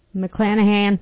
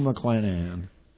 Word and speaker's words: McClanahan: Here is Rue McClain. – McClanahan. – McClanahan? Is that how it's pronounced? – Yes. – Rue McClanahan.